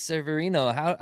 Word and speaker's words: Severino: I don't know how guys like Luis, I can't even pronounce his name, Severino. Thank you. Luis Severino.